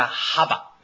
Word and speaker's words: ハバ: すなわち、はい、命の女性形がハバ。ですから、ある先生はですね、エヴァと呼ばないで、ハバと読むのです。